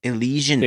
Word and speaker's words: Elysian: Elysian. I don't know how to say the brewery, man. – Elysian.